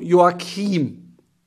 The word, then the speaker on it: Joachim: Auf jeden Fall hier Leon Joachim bei LinkedIn anschreiben, wird aber Achtung, Joachim ausgesprochen. Sehr geil.